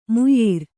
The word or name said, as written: ಮೋಯಿರ